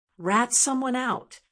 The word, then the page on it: rat someone out: American English